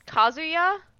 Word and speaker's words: Kazuya: What did you make of, is it Kazuya? I'm not a Tekken guy. Like, how do we pronounce this name? Do we know? – Yeah, I mean, Kazuya?